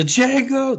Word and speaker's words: Django: The Django, the D is Silent Hillbilly.